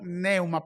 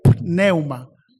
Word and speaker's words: pneuma: Pero esta palabra neuma comienza con P y nosotros decimos neuma, pero la verdad que debería ser neuma, porque comienza con una P. ¿Entendió? Otra vez, la pronunciación correcta debería ser pneuma, por la P que tienen en principio, para que se pueda sentir la verdadera grafía de este prefijo que compone la palabra neumatología.